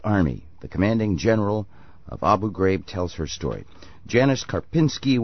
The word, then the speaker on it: Abu Ghraib: but I just want to hear you say it correctly once. – Abu Ghraib.